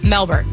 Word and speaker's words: Melbourne: Melbourne. Or Melbourne. Which, Melbourne. Apparently they don't pronounce the R. – Okay. So no R's.